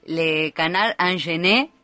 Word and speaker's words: Le Canard Enchaîné: En este momento se habla bastante del semanario satírico Le Canard Enchaîné, ¿lo dije bien? – Muy bien.